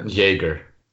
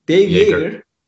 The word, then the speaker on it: Jaeger: E, bence Dave Dave soyadını ben onu telaffuz edemiyorum. Yor, Jorger miydi? Jorger, Jorberger miydi? – Jaeger. – Jaeger. Dave Jaeger.